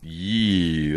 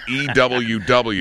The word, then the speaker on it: U: If it's a, they're called yew. That's how you say. That's how you pronounce that. – No, not EWW.